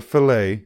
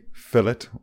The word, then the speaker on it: fillet: I get a chicken fillet, not a fillet, fillet. We pronounce a T in Ireland.